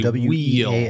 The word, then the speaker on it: Weald: It looks like Weald, but on the stream they pronounced it Weald. – Gotta say Weald.